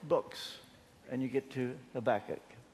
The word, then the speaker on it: Habakkuk: Now, you that don't know better, call it Habakkuk. But I had a word with Habakkuk. He told me he pronounces his name Habakkuk.